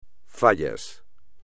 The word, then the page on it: fallas: espagnol